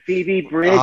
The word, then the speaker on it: Phoebe Bridgers: So I think you pronounced the artist's name as Fabe Bridgers. The song is Kyoto. – Are you talking about Phoebe Bridgers? – Oh, Phoebe Bridgers. – Oh, yeah. – That's how you pronounce it.